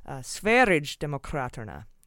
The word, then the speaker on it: Sverigedemokraterna: And for the pronunciation of the right-wing party in Sweden, I found out it's a soft G, like GIF. It's not GIF, it's GIF. And that makes it Sverigedemokraterna.